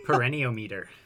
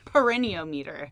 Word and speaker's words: perineometer: Perineometer. – Perineometer.